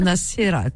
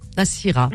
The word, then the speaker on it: Nacira: En plus, il s'écrit comme moi, Nassira. Comment il s'écrit ton prénom? – N-A-C-I-R-A. – Comme moi, hein? Nassira.